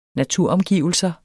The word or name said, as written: naturomgivelser